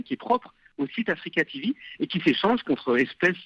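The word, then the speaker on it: AfreecaTV: Alors, ça se prononce Africa TV, mais rien à voir avec le continent africain. – Rien à voir avec le continent africain. C'est le free de libre.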